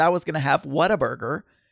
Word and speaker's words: Whataburger: So we went to Whataburger on Friday night. Not Whataburger, but What a Burger. What a Burger. But it's spelled, it's pronounced Whataburger. Not water, Wata. No R. Scotty Battler.